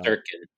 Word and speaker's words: Shesterkin: Uh Shestikin, I don't know how to say his name. – Uh, Shesterkin.